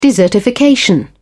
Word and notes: desertification: British English

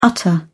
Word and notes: utter: British English

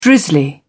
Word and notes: drizzly: British English

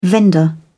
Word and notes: vendor: British English

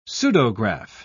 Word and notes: pseudograph: British English

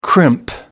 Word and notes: crimp: American English

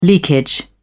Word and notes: leakage: British English

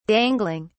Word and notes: dangling: British English

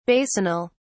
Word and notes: basinal: British English

